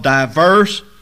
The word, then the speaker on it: divers: And he healed many that were sick of diverse. You see the word divers there. That's just an old English word that is pronounced the same as our diverse.